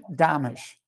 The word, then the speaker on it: Domitrz: So think of Amish and put a D in front of it, Domitrz.